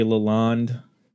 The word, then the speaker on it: Lalonde: The lineup for this album is Les Claypool on vocals, bass, Larry Lalonde. Is that how you say it, Lalonde? – That's how I say it.